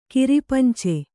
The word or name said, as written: ಕಿರಿಪಂಚೆ